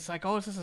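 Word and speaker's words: Psicosis: know everybody calls him psychosis over here, but if you're doing the Spanish uh, pronunciation, it's psychosis.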